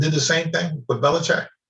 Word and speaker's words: Belichick: You're going to have input, but Belichick.